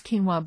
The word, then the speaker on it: quinoa: It's pronounced quinoa, but it's spelled the first letter is a Q.